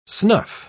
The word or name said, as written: snuff